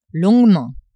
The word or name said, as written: longuement